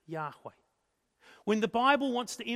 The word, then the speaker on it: Yahweh: Older generations use Jehovah, but scholars think Yahweh is probably the closest pronunciation we can get.